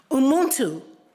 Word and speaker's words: Ubuntu: Just to impress you, I learned how to say these Zulu words, so I'm going to say it. Ubuntu, Ngubuntu, Ngubuntu.